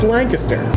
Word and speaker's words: Lancaster: Next stop, oh wait, let's ask the locals how to pronounce it. – It's Lancaster. – Lancaster. Lancaster. – Yeah, it's Lancaster, Pennsylvania, that is.